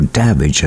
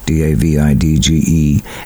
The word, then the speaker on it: Davidge: And Shanaganak is Shanaganock with Bud Davidge, or Davidge as they pronounce it in Newfoundland, Davidge, and the song Music and Friends.